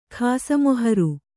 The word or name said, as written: ಖಾಸಮೊಹರು